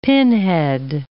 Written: pinhead

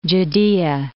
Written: Judea

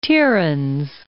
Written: Tiryns

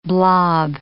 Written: blob

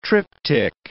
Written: triptych

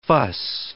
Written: fuss

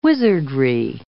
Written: wizardry

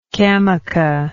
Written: camoca

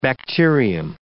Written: bacterium

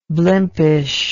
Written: blimpishness

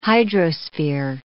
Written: hydrosphere

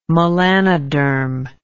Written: melanoderm

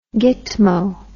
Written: gitmo

